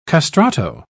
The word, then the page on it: castrato: British English